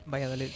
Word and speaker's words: Valladolid: Valladolid, lo dije bien. – Valladolid, sí.